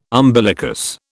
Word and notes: umbilicus: British English

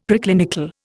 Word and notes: Preclinical: Indian

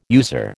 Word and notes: user: American English

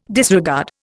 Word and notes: disregard: Indian

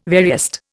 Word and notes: veriest: Indian

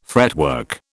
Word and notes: fretwork: British English

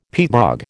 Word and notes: peatbog: American English

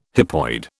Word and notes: hypoid: British English

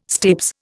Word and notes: stapes: Indian

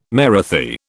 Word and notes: Marathi: British English

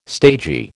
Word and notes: stagy: American English

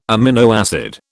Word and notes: amino acid: British English

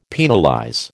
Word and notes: penalize: American English

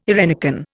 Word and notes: eirenicon: Indian

